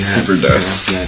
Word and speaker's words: SuperDesu: And I am here today with a band I can't pronounce, so you guys can Superdust. It's the Superdust with a silent U on the end, so it's Super and then D-E-S-U.